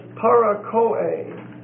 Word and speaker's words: parakoe: The next word is parakoe. P-A-R-A-K-O-E. P-A-R-A-K-O-E. It's a long E.